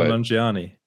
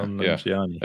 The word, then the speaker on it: Nanjiani: Camille Nanjiani. – Yeah, Nanjiani.